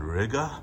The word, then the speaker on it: Riga: Honestly, I like Lviv a little bit more than Riga, which rhymes with nigga.